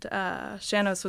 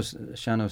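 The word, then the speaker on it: sean-nós: And Shannos was one of those. So I fell in love with it and haven't looked back. – And Shannos, by the way, is spelled, for those who didn't, well, you wouldn't know anyway, but it's, unless you happen to know, S-E-A-N-N-O-S.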